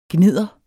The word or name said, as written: gnidder